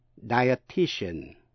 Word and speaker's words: dietitian: Sau đây là chữ die, D-I-E, phát âm là die, nhưng mà diet, D-I-E-T, phát âm là hai âm tiết, diet. Die, diet, dietitian, dietitian, she works as a dietitian, cô ta là chuyên gia về khoa dinh dưỡng, dietitian, bốn âm tiết.